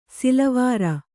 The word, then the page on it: ಸಿಲವಾರ: silavāra